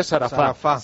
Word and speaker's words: Zarafa: Zarafa, que se pronuncia Zarafa.